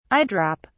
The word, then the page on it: eye-drop: British English